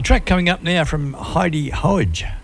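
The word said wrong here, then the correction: Haug: Heidi Hage. H-A-U-G, I think it's how she spells it, pronounces it.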